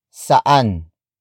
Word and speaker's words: saan: Word for word, it's saan ang pinakamalapit na bar.